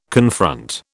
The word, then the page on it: confront: British English